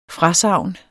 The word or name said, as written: FRASAGNENES